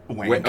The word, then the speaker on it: Wanyk: Like the Y is silent. W-A-N-Y-K.